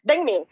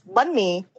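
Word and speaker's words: bánh mì: But I think the easiest pronunciation is bun me or bang me.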